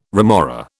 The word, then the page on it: remora: British English